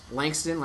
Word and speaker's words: Langston: R-H-Y-S, as in Rhys, R-H-Y-S, no vowels. Langston, like Langston Hughes.